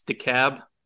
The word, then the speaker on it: DeKalb: It's DeKalb, not DeKalb or DeKalb or any other pronunciation. It's DeKalb, like why call a cab when you can call DeKalb? – DeKalb.